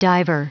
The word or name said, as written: diver